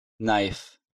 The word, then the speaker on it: knife: Буква K читается как N в сочетании KN. – Knee, knife, no, night.